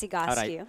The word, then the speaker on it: Egoscue: First of all, people can't pronounce it correctly. It's Egoscue.